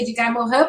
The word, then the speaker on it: Edgar Morin: É, mas a gente pronuncia Edgar Morin, porque é o nome francês.